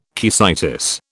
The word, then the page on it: caecitis: British English